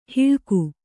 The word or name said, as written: ಹಳಗ